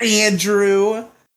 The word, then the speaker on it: Andrew: So every time someone in school would say Andrew, but have a put stress on the A syllable, like how Riley does, when I'm yelling at you, like Andrew, like that.